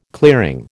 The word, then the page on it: clearing: American English